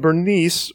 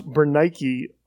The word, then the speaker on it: Bernice: Verse 13, "Now when some days had passed, Agrippa the king and Bernice," or it's probably pronounced Bernice, "arrived at Caesarea and greeted Festus.